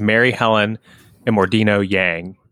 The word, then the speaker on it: Mary Helen Immordino-Yang: And so you you cite and I, I may not say her name correctly, but I think it's Mary Helen Immordino-Yang. – Correct.